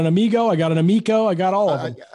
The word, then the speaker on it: Amico: The guest of the show, Tommy Tallarico, you are the CEO of Intellivision, and you guys have a product, Amico, which rhymes with Tallarico.